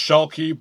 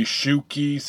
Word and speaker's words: Schuelke: It's pronounced Schuelke, but say Schuelke, say Schuelke, however you want.